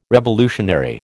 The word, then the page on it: revolutionary: American English